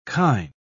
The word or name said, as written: kine